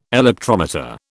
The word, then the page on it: electrometer: British English